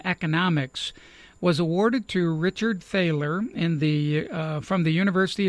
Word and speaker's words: Thaler: And he writes this article about aspects of the work of Richard, I think it's pronounced Thaler. It's T-H-A-L-E-R. So I think it's Thaler.